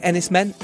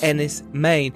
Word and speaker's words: Enys Men: Don't be confused by the poster. It looks like Enys Men. It's pronounced Enys Men. And in Cornish, it means Stone Island.